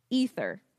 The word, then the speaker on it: aether: But yeah, so it's like ether is how you say it.